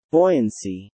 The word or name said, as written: buoyancy